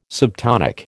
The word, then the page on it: subtonic: American English